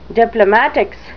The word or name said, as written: diplomatics